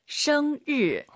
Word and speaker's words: 生日: 정말 많은 분들이 生日, 生日, 르. 한글에 르 있잖아요. 그게 들리면 안 되세요. – 没错. – 生 하시고 입 벌린 상태에서 生日. 혀만 천장에 대면서 드럭 生日.